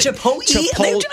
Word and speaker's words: Chipotle: And the other one, I don't know what it is, but some people I think are physically incapable of pronouncing the word Chipotle correctly. – Chipotle. I hear Chipotle so often, but somehow the L goes before the T in that word, and I'll never understand how or why.